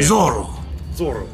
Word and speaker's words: Zorro: Well, now you can't roll your R's, so that's why you can't say Zorro. – Well, damn. – Zorro.